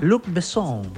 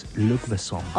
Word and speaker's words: Luc Besson: Ay, pará, pronunciarlo de vuelta. – Luc Besson. – Pará. ¿Cómo estás diciendo? – Sí, sí, eso igual sale, sabe el francés. Este es Luc Besson.